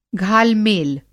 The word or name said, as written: घालमेल